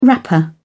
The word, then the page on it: wrapper: British English